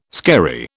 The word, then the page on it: skerry: British English